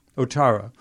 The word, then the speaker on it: Ouattara: Is it pronounced Ouattara? Anyway, Ouattara.